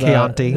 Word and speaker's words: Chianti: Chianti? Isn't that what... – Chianti. – Isn't that what... – Hannibal? – Yeah. Yes, Hannibal. – Yeah. Yeah. Type of wine. – Yeah. Anyway. – Chianti.